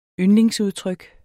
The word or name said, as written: yndlingsudtryk